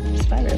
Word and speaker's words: Spiro: Is that how you say it? Sienna Spiro? – Spiro, yeah.